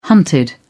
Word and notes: hunted: British English

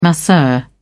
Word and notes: masseur: British English

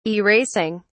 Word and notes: erasing: British English

